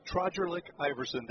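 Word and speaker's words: Trogrlic-Iverson: And Nick Trojerlik Iverson, hyphenated last name. It's a dandy. T-R-O-G-R-I-L-I-C. I think I just added a letter to that. But it's pronounced Trojerlik Iverson, that much I know.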